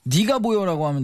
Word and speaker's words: 니가 보여: 이건 어떻게 발음을 해야 되나요? 니가 보여라고 하면 됩니다.